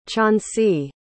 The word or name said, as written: choncey